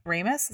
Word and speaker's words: Ramis: Yeah, Egon Spengler, who is Harold uh, Ramis, Ramis, is how you say it? – Mm-hmm.